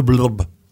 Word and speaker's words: blob: Et qu'elle est devenue célèbre dans le monde entier autour de ses travaux sur le fameux blob. – Exactement. – Je prononce bien ou pas? – Oui, c'est ça.